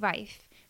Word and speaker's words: Ralph: I think he pronounced it Rafe. Rafe Fiennes.